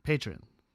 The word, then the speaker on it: Patreon: Patreon. Ja, jeg tror det er sånn. Patreon. Ja, det er jo sånn det uttales.